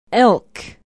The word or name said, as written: elk